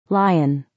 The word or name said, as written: lion